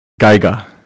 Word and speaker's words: Geiger: Geiger.